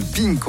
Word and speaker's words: PINC: Alors ça se prononce Pink phonétiquement, mais ça s'écrit P.I.N.C.